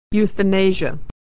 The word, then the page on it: euthanasia: y